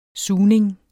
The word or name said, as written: sugning